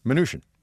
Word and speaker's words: Mnuchin: How do you say it? – Mnuchin. – Mnuchin, like minutia.